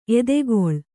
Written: ಎತ್ತುಗಲ್

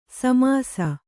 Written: ಸಮಸು

